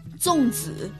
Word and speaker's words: zongzi: ¿Cómo se pronuncia Songzi? – Zongzi. – Zongzi. – Zongzi. – Zongzi. – Sí, dos seda, Zongzi.